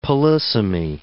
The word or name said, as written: polysemy